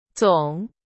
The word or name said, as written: 总